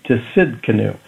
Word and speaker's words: Tsidkenu: Now, when I was a brand new Christian and I heard this, I thought, oh, how cool, what a name, Tsidkenu. And that means the Lord our righteousness. So that Hebrew word is spelled T-S-I-D-K-E-N-U.